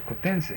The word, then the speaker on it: cotense: And we mostly, everybody down here, know it as a cotense. – Is it what? – Cotense. – And how do you spell that? – C-O-T-E-N-S-E.